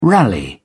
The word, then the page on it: rally: British English